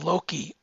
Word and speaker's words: Loci: But Rai and Raijin visit this strange house looking for remnants of father and run into an AI called Loki, and it's spelled LOCI, that wants them to stay in the house but permanently.